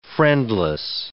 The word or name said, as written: friendless